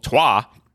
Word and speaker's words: Troyes: Trois. That's Troyes for those who don't don't pronounce it that way.